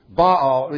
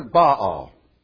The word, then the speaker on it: Baal: Baal, they sometimes call him Baal, but it's really two syllables.